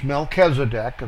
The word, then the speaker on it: Melchizedek: That's Melchizedek, if you want to pronounce it the English way.